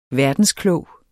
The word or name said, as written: VERDENSKLOGE